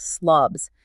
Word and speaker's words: SLBS: Now for the orders placed above the current market price, the acronym is SLBS, pronounced SLOBS, sell limits and buy stops.